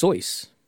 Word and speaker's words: Seuss: Did you know it's supposed to be pronounced Soyce? – No. – As in rhymes with rejoice.